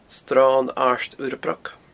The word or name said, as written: sronairdurrabroc